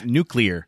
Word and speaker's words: nuclear: How do you say it wrong? – Nuclear. I can't. Nuclear is how you say it correctly.